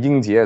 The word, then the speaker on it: Yingjie: Like my name Yingjie is actually very hard to, hard to pronounce. Like people have really tr- tremendous trouble. They say Ji instead of Jie.